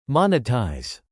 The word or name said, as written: monetize